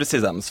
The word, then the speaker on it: CESAMS: Vous êtes doctorant à Normandie Université, au Centre d'études sport et action motrice, c'est le CESAMS? – C'est le CESAMS, voilà. – On prononce comme ça.